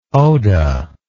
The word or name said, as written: odah